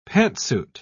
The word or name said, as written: pantsuit